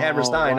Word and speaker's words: Hammerstein: And he was like, hey, you don't call the Steiner brothers the Steiner brothers, do you? It's spelled the same way, so oh, it's Hammerstein.